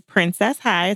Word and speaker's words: Princess High: My name is Princess High.